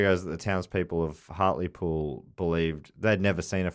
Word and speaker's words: Hartlepool: What's it called? Hartley Pool? – Hartlepool. – That's how you say it. I got in trouble for saying Hartlepool because it's Hartlepool.